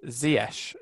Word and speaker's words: Ziyech: I mean, you don't know James Justin's name, and also it's Ziyech.